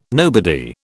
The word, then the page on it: nobody: British English